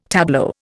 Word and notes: tableau: Indian

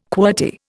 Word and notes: qwerty: Indian

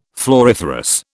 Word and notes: floriferous: British English

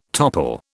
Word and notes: topple: British English

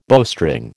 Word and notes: bowstring: American English

